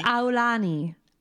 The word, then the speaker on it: Aulani: So Tana visited Alani. Aulani. – We don't have to say it all Aulani. – Weird like that. – It's not weird. That's how you pronounce it, right? – Aulani. It's Aulani.